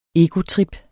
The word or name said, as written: egotrip